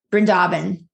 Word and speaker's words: Vrindavan: I went to Brindaban. I went to Vrindavan is how you say it, right? I always say it wrong, but Vrindavan.